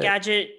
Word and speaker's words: Skagit: It was in, was it Skagit? Is that how you pronounce it? – Skagit Valley.